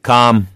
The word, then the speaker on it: calm: Calm. – 네, C-A-L-M 할 때 L이 묵음이에요. Calm이죠, calm.